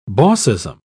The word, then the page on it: bossism: British English